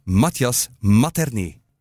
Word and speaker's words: Matthias Materné: Welkom, Matthias Materné. Heb ik dat allemaal juist uitgesproken? Volledig juist. Uitstekend.